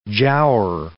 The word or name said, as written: giaour